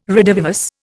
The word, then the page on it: redivivus: Indian